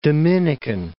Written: Dominican Republic

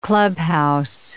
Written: clubhouse

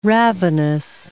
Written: ravenous

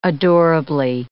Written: adorably

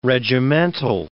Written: regimental

